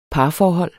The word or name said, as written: parforhold